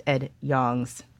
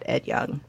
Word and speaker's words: Ed Yong: And everyone points out that I mispronounced Ed Yong's name. Ed Yong, not Ed Young.